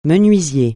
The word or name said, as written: menuisier